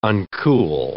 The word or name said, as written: uncool